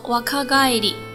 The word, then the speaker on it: 若返り: 若返り这个词呢，是一个似有若无的若字加上帰る返回，它的名词形式帰り，所以说读作若返り。